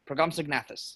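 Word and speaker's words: Procompsognathus: So Hammond is killed by a pack of Progomsnognathus. Does that ring a bell? – I think you might be emphasizing the snog a little more than the normal pronunciation would call for, but.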